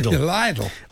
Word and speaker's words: Lidl: He said, referring to the Irish pronunciation of Lidl, my lovely stepmother has a touch of the hyacinth bouquets about her and always pronounces Lidl as if it rhymes with idol. – Lidl.